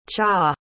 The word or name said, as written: cha